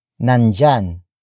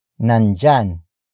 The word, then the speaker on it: nandiyan: Word for word it's nandyan pa ba siya. Nandyan pa ba siya. Nandyan pa ba siya. The first word is nandyan. Nandyan. Nandyan. Nandyan is spelled as N-A-N-D-I-Y-A-N.